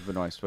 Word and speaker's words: Benoist: Maybe that's how they introduce Melissa Benoit in the episode. Benoist, or however you say her name. – Yeah, it was Benoist.